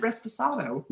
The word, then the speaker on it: Reposado: The Reposado. – You said. Oh, you did say it right. – I said it correctly. Yeah. – you said it. – Yeah. – You said Reposado.